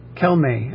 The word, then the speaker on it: Calmet: And according to Who Be Gone, Kelme actually would be the pronunciation of the French name.